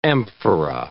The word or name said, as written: amphora